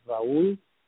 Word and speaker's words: Raul: My address, my personal email, which I gladly give it to you if anyone would like to share something, or just Raul Medina. I will spell it. That's R A U L M E-D-I-N-A.